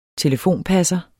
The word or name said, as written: telefonpasser